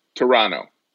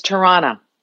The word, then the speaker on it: Toronto: Toronto. – It's Tirana. – Tirana. – Yeah, the second T is silent. Tirana.